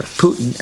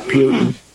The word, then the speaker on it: Putin: The a great column today from actually yesterday from Pat Buchanan, Will Putin, or Putin. Actually, I just mispronounced his name. I corrected people for years on that. It's Putin, not Putin.